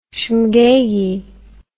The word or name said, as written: shmegeggy